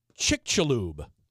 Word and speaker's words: Chicxulub: Now, in Spanish, that has a sh sound. – Mm-hmm. – I'm not sure if, in fact, they even have it right in their pronunciation guide. Because it says Chicxulub.